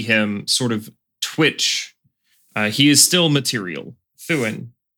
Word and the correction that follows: Thurin: I have to roll the R. Yes. I should be able to roll R's. I'm Canadian. – Yes, you do have to roll the R. That is how it is pronounced.